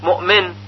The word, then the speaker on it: مؤمن: مثلا کلمه مؤمن، مؤمن، مؤمن، که اکثر قراء هم به همین صورت که تلفظ شد تلفظ میکنند، مؤمن، مؤمن.